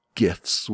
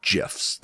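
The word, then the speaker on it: GIF: And yes, it's GIFs with the hard G, not GIFs. That would be a J, yeah, or peanut butter. Yeah, it's a brand of peanut butter, people.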